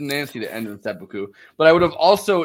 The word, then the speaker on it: seppuku: More biopic should end in seppuku. How do you say it? Seppuku? – Seppuku.